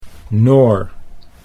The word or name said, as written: Noor